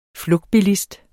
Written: flugtbilist

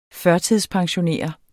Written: pensionskunde

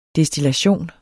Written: destillation

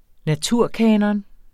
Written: naturkanon

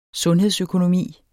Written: sundhedsøkonomi

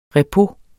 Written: repos

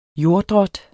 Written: jorddrot

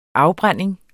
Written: afbrænding

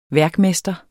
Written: værkmester

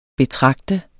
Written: betragte

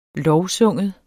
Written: lovsynge

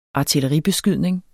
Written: artilleribeskydning

